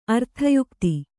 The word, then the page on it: ಅರ್ಥಯುಕ್ತಿ: Kannada